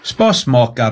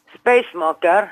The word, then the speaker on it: Spasmaker: Spasmaker du. – Spasmaker. – Nei, S-P-A-S. – Ja. – M-A-K-E-R. Spasmaker, nei, det skal uttales på norsk, spasmaker.